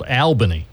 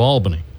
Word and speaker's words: Albany: Sometimes they claim that our capital is in a place called Albany instead of Albany.